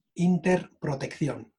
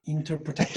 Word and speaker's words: Interprotección: So it's Interprotección. Interprotección.